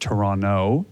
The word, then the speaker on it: Toronto: We pronounce it Toronto, no T at the end.